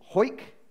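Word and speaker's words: Hawick: and we went to this town called Hoyk, which is spelt really odd. It doesn't look like Hoyk, but there it is.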